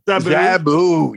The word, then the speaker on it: Zabu: Zabu, yeah.